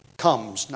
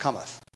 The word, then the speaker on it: cometh: they would have said, "Comes, not cometh."